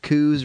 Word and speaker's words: coups: Coups, Restraints, and Bad News. – I wasn't there. – That's a silent P on the end of coups, by the way.